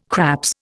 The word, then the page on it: craps: Indian